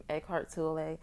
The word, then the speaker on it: Eckhart Tolle: And that's by Eckhart Tolle. I believe that's how you pronounce his name, Eckhart Tolle.